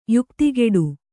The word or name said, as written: ಯುಕ್ತಿಗೆಡು